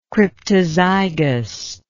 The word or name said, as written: cryptozygous